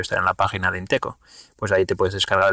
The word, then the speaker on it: Inteco: Y nada, entonces si alguien está interesado en saber cómo funciona Bitcoin, pues tiene el PDF este de Inteco, que se, se escribe así como suena, Inteco, I-N-T-E-C-O,